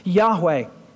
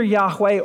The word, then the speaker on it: Yahweh: Rarely was it ever spoken, and so it's probably pronounced either Yahweh or Jehovah. Either way, we're not really sure because it was never pronounced, and so it's the best guess of most scholars that Yahweh is probably the best way to say it, but God is revealing himself as a personal God.